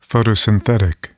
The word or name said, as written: photosynthetic